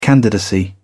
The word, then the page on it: candidacy: British English